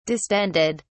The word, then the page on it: distended: British English